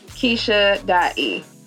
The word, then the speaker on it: Keysha: It's Keisha.E. K-E-Y-S-H-A. – K-E-Y-S-H-A. You spell it. – E-Y-S-H-A.